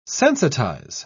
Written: sensitize

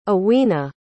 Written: awena

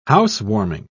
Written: housewarming

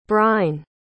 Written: bryne

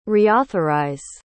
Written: reauthorize

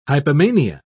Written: hypomania

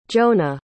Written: jonah

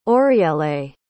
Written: oriele

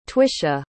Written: tvisha

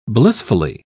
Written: blissfully

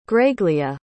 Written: graegleah

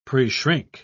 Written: preshrink

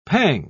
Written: pang

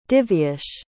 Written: divyesh

